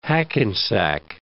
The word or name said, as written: Hackensack